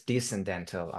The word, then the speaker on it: descendental: Now, yeah, also thank you for correcting my pronunciation on that. It's descendental. I think I said descendental or something.